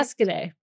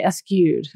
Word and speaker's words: Escudé: Almost got the emphasis on the right syllable. – Yes. Well, at least you didn't say eschewed. That's what I get a lot.